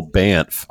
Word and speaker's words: Banff: Most Americans, speaking of smart, fun Americans, won't know The Gathering, but it's an event in Canada in beautiful Banff. I said it correctly, I think, near Calgary.